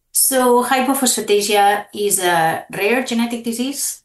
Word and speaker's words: hypophosphatasia: What is hypophosphatasia? Did I get that right? – Yes.